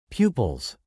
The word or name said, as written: pupils